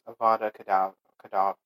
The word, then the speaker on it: Avada Kedavra: Well, it's Avada Kedavra.